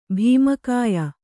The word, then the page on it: ಭೀಮಕಾಯ: Kannada